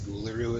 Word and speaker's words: Uluru: I think it's Uluru is how you pronounce it.